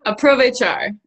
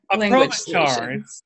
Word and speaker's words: Aprovechar: Aprovechar. – Aprovechar. It's a long O instead of a short O.